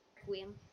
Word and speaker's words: Requiem: How do you say that word? – Requiem. – Requiem.